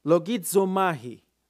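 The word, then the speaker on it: Logizomahi (λογίζομαι): Logizomahi, that is how it pronounced.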